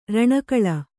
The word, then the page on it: ಋಣಗೂಳು: řṇagūḷu